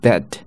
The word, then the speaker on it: bed: 后面这个是哎，bed bed，嘴是很小，两边也张得不是那么开。